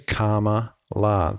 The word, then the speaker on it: Kamala: Of course, and I won't say it now because then I'll get into the habit of saying it, but it's actually Kamala. So you think of karma, good karma, Kamala Harris.